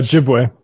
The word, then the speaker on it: Ojibwe: Am I saying this right? The Ojibwe tribe from Leaks or Leech? Yeah, Ojibwe.